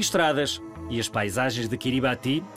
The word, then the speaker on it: Kiribati: Kiribati. É assim que deves pronunciar o nome das ilhas Kiribati. Sabes porquê? Porque é assim que o fazem os seus habitantes.